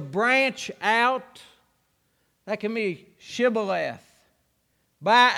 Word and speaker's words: Shibboleth: And they said, "Say Shibboleth." And they said, "Chevrolet." "Sibylay." They said, "No, you're an Ephraimite." And they killed him. That's what they did. Had a grammar problem. They couldn't pronounce Shibboleth.